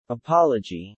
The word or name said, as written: apology